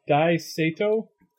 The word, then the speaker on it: Dai Sato: Dai, I believe it's pronounced Dai Sato.